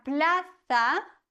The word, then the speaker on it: plaza: En la plaza. Z wird gelispelt im Castellano.